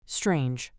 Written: strange

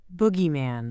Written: boogeyman

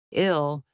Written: ill

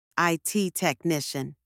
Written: IT technician

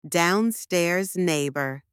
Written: downstairs neighbour

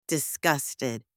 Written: disgusted

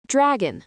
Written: dragon

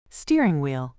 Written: steering wheel

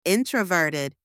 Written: introverted

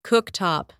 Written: cooktop

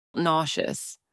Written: nauseous

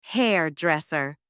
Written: hairdresser

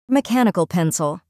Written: mechanical pencil

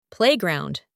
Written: playground

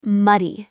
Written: muddy

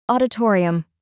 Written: auditorium

